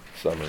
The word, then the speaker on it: Somer: Summer, yes. S-O-M-E-R, but it's pronounced Summer.